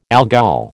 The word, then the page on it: Algol: American English